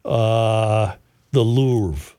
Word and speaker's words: Louvre: See, as a tourist, when I visited, say, the Louvre.